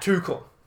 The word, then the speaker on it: Tuchel: Thomas Tuchel. – Not bad. Tuchel.